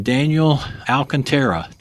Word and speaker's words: Alcantara: So today, I'm still here at the Connected Workers Conference, and my guest today is Captain Daniel Alcantara.